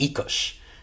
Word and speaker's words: Oikos: You've been pronouncing it wrong your whole life. It's actually pronounced Oikos, and it means home or household in Greek.